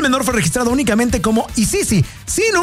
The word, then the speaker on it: Isisi: Básicamente se escribe I latina, S-I-S-I. Básicamente se pronunciaría Isisi.